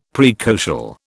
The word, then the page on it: praecocial: British English